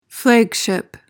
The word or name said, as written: flagship